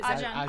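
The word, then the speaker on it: Ayja: It's A-Y-J-A. So is Ajahn.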